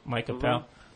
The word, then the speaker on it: Appel: And along those lines, Tom, you know all about Mike Appel, is that how you pronounce his name? Micah Apple? – Yeah. – Mike Appel.